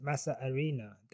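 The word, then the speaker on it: masa harina: I've just been told by Mario, that my manager, my Massa Harina pronunciation was wrong. The H is silent. Massa Arena.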